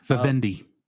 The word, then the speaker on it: Vivendi: Uh, Vienna, Vienna, uh, Entertainment, or V I V E N D I. Vivendi. Uh, Vivendi.